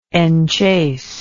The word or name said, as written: enchaser